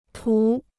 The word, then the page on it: 图: tú